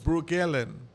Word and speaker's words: Breukelen: Brookellen. Mm-hmm. Brookellen. Yeah. You see how it's spelled? – Right. B-R-E-U-K-E-L-E-N. Right. – Dutch. Dutch, that's the Dutch name.